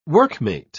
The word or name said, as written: workmate